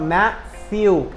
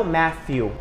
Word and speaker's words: Matthew: Então, Matthew, e não Matthew. Não com F, mas com TH.